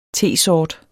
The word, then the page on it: tesort: [ ˈteˀˌsɒːd ]; [ ˈteˀˌsɒˀd ]